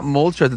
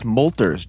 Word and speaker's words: Moltres: It's not Moltres, it's Molters.